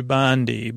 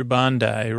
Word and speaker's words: Bondi: And from my next spot, we're floating in a place I would have pronounced incorrectly. I would have called you Bondi, but you're Bondi, right? I say Bondi, then you correct me.